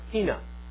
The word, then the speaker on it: hina: Greek, it's like I-N-A, almost like my name, but it's pronounced hina. Hina, because I has a little inflection, so it's hina, I-N-A.